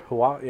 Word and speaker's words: Huawei: Huawei, yeah, that's how you say it.